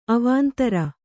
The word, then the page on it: ಅವಂದಿರ್: avandir